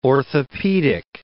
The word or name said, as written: orthopaedic